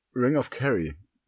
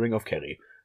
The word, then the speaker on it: Ring of Kerry: Ring of Kerry. Mhm. Und den spricht man tatsächlich so aus. Ja? Das ist der Ring of Kerry. auf, auf Curry.